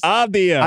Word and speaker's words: Avdija: How do you say it, House? – Avdija. – I just can't say it. Avdija. – Yeah.